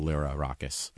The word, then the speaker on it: Lyrarakis: So Lyrarakis, if you can't roll your R's.